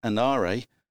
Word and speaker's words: ANARE: to take the first Australian National Antarctic Research Expedition, pronounced ANARE, south to Antarctica.